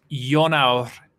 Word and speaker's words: Ionawr: Pronounce this Welsh word for me, Zach. I-O-N-A-W-R. Words are always great when they start with I-O-N, right? – Inwr. – One more time. – Inwr. – It's Jonaur.